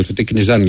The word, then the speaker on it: Τικνιζιάν: Ο Τικνιζιάν με τον Τικνιζάν, γιατί δεν προφέρεται, λέει, είναι μένει με το Α. Τικνιζάν, λοιπόν, να το λέμε.